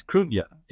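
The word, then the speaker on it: Cruithne: The second question, yeah, it's Krunya, it's pronounced. It's I think it's spelled C-R-U-I-N-T-H-E, I think, right.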